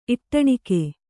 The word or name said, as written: ಇಟ್ಟಣಿಕೆ